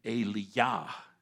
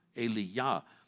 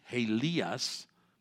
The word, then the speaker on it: Elijah: In Hebrew, Elijah is pronounced Eliyah, Eliyah. In Greek, Helias.